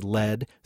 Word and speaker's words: lead: So the metallic element, lead, is spelled L-E-A-D. The past tense of the verb lead is led, spelled L-E-D. – L-E-D sounds familiar.